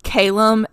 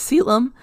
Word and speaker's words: Caelum: Okay, so obviously this guy's name is spelled C-A-E-L-U-M. And I said it. I switched between like Calum and Callum for a long time, and then I looked it up and it was Selim. So like that's when I started calling him Selim, and it just kind of like clicked from there.